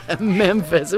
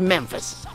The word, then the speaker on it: Memphis: Memphis. It's got the capital M-E just for my initials, but it's Memphis.